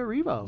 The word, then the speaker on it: Erivo: Cynthia, how do you pronounce her last name? Erivo? – Cynthia Erivo.